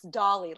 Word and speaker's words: Dolly: It's it's Dolly, like Hello Dolly.